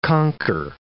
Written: conker

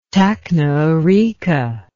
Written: tacnaarica

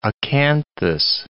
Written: acanthus

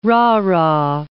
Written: rah-rah

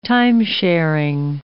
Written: time-sharing

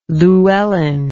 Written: Llewellyn

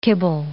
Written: kibble

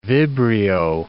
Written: vibrio

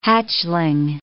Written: hatchling

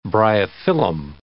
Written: bryophyllum

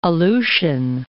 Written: Aleutian Islands

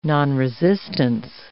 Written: nonresistance

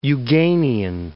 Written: Euganean Hills

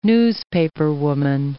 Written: newspaperwoman

